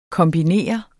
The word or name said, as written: kombinere